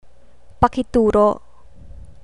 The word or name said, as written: pakituro